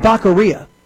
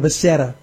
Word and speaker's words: Becerra: Oh, that's how Beijing Biden pronounced his good friend's name. You know, Javier Baqueria was such a good friend and so well vetted by Joe Biden that he couldn't even pronounce his name. It's Javier Becerra, folks, a loudmouth leftist Latino.